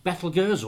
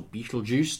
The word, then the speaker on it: Betelgeuse: So last month we talked about Betelgeuse, or Betelgeuse, depending on how you want to pronounce it.